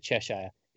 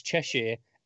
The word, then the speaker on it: Cheshire: I'm getting that out of the way first because I knew both of you guys pronounce it Cheshire. It's Cheshire, and if she's a recurring character, we're getting that right now.